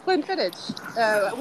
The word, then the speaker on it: Glenfiddich: The brand is Glenfiddich. It's a green one. – Oh, yes, of course, Glenfiddich.